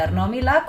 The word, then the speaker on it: Arnau Millà: Il Sound Painting, un workshop e performance con Arnau Millà. E mi perdoni Arnau Millà se la pronuncia non è esatta. – Dovrebbe essere giusta, si faceva chiamare così quando l'ho incontrato quest'estate.